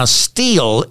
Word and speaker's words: Stihl: Now, steel is spelled S-T-I-H-L.